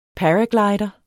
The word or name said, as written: paraglider